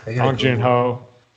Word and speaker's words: Bong Joon-ho: Bong Joon-ho.